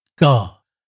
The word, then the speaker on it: Gar: Gar. Pronounced with a hard G.